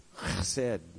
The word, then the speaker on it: chesed: And the Hebrew word is chesed. All right? If you want to say it like that. Chesed.